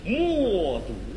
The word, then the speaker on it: 冒顿: 是，是，是，但是冒顿在匈奴的本音应该读成莫读。